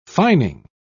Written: fining

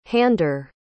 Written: hander

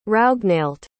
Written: raoghnailt

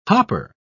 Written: hopper

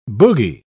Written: boogie

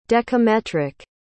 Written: dekametric

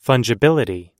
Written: fungibility

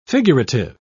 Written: figurative

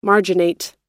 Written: marginate

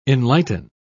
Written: enlighten

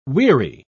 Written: weary

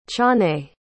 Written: ciannait

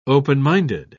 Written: open-minded

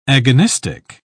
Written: agonistic

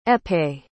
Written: épée

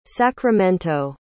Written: sacramento

